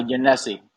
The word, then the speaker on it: Yonessi: Uh, Yonessi. So it's like Yo Messi, but with an N. Yo Nessie.